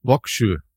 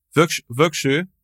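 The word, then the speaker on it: Vågsøy: Vöksoy. – Vöksoy. – Vöksoy. Ein Ö ist eine Insel. Also heißt Insel. – Ja, im norwegischen Vöksoy am 27.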